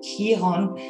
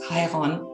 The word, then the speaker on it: Chiron: Wenn wir nochmal zurückgehen in unser Bild von den Planeten, dann ist es genau Chiron oder Chiron, griechisch ausgesprochen wäre es Chiron.